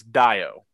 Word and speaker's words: Dayo: I know for a fact his first name is spelled, it is pronounced as if you were looking at it, D-Y-E hyphen O. So it's Dio.